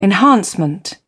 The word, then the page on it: enhancement: British English